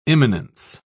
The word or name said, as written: imminence